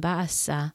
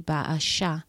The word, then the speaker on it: Baasha: And I just looked up 1 Kings 16, and actually I was pronouncing the name Baasa wrong. His name in Hebrew is actually Baasha, like with a S-H.